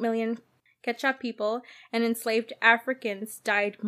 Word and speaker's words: Quechua: Quechuan, I think I said it right. Quechuan people. – I think it's just Quechua. – Quechua? Like, yeah, I don't think you say the U or the E.